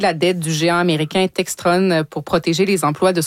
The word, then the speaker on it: Textron: Oui, ce sont des informations qui ont été obtenues par notre collègue Thomas Gerbet qui montre que Bell Textron, qui possède Textron, il le prononce comme ça en anglais, évidemment.